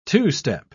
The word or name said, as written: two-step